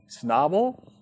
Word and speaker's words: Schnabel: And I have a quote there in the bottom of your notes from another commentator, Eckhard Schnappel. I don't know how to pronounce that name. S-C-H-N-A-B-E-L. How do you pronounce that? Schnabel? Schnabel. Okay, thank you.